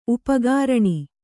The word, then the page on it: ಉಪಕರಣ: upakaraṇa